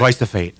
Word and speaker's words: glyphosate: Glyphosate.